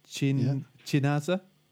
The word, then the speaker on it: Chinaza: It's spelled, the first name is like China with a ZA on the end. – All right. – Chinaza? – Yeah.